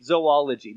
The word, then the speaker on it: zoology: That's right, zoology, the study of animals. Yes, there's no zoo in zoology. That's how you pronounce it.